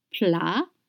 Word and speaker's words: plats: D'abord, first, you have to know that plat, P-L-A-T-S, plat in French means dishes or main dish. The spelling is P-L-A-T-S, and the pronunciation is plat. You don't pronounce consonants at the end of French words.